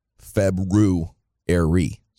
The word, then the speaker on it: February: There is an R after the B. February.